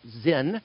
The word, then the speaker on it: Sin: We pronounce it Sin because it's spelt that way. In Hebrew, it's pronounced Zin.